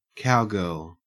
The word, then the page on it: cowgirl: Australian English